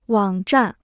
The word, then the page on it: 网站: wǎng zhàn